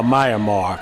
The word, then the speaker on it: Myanmar: It's now Myanmar, if I'm saying that right. – Right.